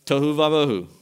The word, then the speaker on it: tohu vavohu: In fact, it rhymes in Hebrew. Tohu vavohu is what it says.